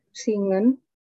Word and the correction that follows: singen: Coba, Zingen. Zingen. Jadi S itu kalau di Jerman bacanya Z ya, teman-teman.